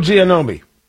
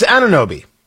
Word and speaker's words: Anunoby: And I am no expert on OG Anunoby. I'm not even pronouncing his name right. It's Anunoby. Sorry.